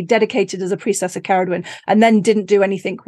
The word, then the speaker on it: Ceridwen: listen to it pronounced in Welsh, everyone. Hopefully, after my training, I'll be pronouncing it in Welsh too. Not Ceridwen, Rebecca, Ceridwen.